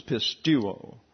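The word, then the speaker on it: pistuo (πιστεύω): The EU is a diphthong and gets a long U sound. So it's pistuo.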